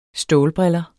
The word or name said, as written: stålbriller